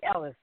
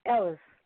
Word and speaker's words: Ellis: Ellis. I'm going to spell it. Shakaysha, C-H-E-K-E-S-H-A, K-K-A-Y, Ellis, E-L-L-I-S.